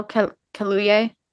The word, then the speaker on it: Kaluuya: And also, I want to say, Daniel, was it Kaleo? Is it how do you say his name? – Daniel Kaluuya. – Kaluuya.